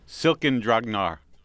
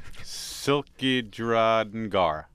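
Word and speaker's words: Silkidrangar: Here's my attempt at pronouncing the name of the record. It's called Silken Dragnar. Nope, that's not right. Silkidragnar. – Perfect.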